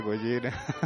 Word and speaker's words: Gojira: ah, bueno, dinos, dinos, dinos, Bueno, Godzilla es la pronunciación correcta de Gojira.